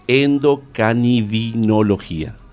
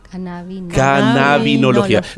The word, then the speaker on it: cannabinología: Y unicista y posgrado también en endocanibinología. Cannabinología.